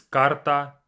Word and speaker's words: карта: Card is карта, we have the I at the end, карта.